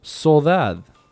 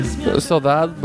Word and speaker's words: Sodade: Well, I'm going to play a song by her called Soldad. But Soldad is spelled S-O-D-A-D-E.